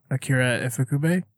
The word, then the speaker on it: Akira Ifukube: And the music was done by, help me out, Akira Ifukube. Did I say that wrong? – No, you said that right.